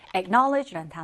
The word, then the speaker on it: acknowledge: acknowledge라는 단어, 발음이 조금 어려울까요? knowledge라는 단어에다가 ac를 붙인 거라고 생각하시면 acknowledge 이렇게 발음이 됩니다.